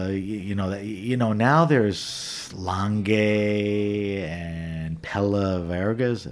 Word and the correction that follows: Pelaverga: Am I pronouncing it right? yep, Pelaverga.